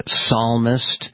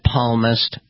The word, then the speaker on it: psalmist: And yes, I noticed, as I'm sure some of you did, the president-elect mispronounced the word psalmist and said palmist.